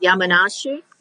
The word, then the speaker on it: Yamanashi: Yamanashi? – Yamanashi, yeah. – Okay, everyone say Yamanashi. Okay. – Yamanashi, yeah. – Okay. So tell us about Yamanashi. Where is Yamanashi exactly? – Yamanashi is located in the center of Japan and 100 kilometers from Tokyo.